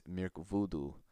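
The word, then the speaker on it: Mirkwudu: Projected into Old English, the term also appears as Mirkvudu. W's in Old English are pronounced with a V.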